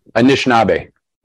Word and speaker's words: Anishinaabe: If I'm pronouncing it right, it's Anishinaabe. – Anishinaabe.